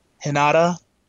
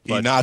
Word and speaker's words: Hinata: Um, the things that weren't shows that trended: uh, Kiba trended, uh, Hinata. – Did I say that right? Nope. – Hinata.